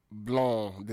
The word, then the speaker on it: blanc: Blanc. The C is silent.